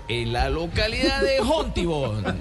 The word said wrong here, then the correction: Fontibón: Fontibón, Fontibón.